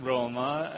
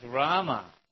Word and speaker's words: Rama: If the people would say, it's not Roma, it's Rama.